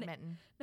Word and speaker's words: badminton: It's got badminton. – It's got an N in it. It's B-A-D-M-I-N-T-O-N, badminton.